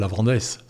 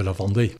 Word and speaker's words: Lavandais: Et puis, attention, en vrai, Cantalou, c'est pas le Lavandès, c'est le Lavandais. – Le Lavandais. – C'est le Lavandais. – On prononce pas le S.